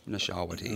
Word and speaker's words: Nashawaty: Nashawaty.